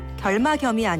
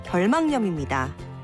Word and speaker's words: 결막염: 결막염이 아니라 결막염입니다.